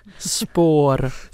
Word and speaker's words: Spår: And Spår is spelled S-P-A-R with one of those cute little circles over the A.